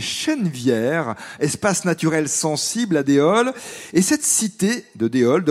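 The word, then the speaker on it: Déols: Déol, ça s'écrit D-E-O-L-S, est-ce qu'on n'entend pas donc, et elle fait partie de Châteauroux Métropole.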